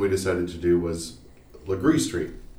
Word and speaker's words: Legare: Or colloquially known by outsiders as Laguerre. – Laguerre. So Laguerre is spelled L-E-G-A-R-E, but it is pronounced Legree. So like L-E-G-R-E-E. So this is one that doesn't look like it sounds.